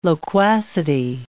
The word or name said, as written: loquacity